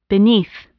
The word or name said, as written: beneath